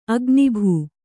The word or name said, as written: ಅಗ್ನಿಭೂ